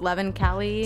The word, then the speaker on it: Leven Kali: I think it's pronounced Levin Kelly. – Sounds about right.